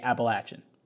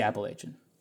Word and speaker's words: Appalachian: if I am thinking ahead to what someone might say, I would try to get ahead of it and say Appalachian, right? But I don't natively say it that way. I say Appalachian.